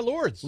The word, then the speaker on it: Lourdes: Yeah, Lourdes.